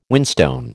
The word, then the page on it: whinstone: American English